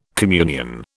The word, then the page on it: communion: British English